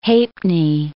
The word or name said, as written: ha'penny